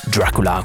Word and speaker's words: Dracula: Bisogna pronunciarlo così, Dracula, come lo dicono loro.